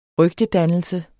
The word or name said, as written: rygtedannelse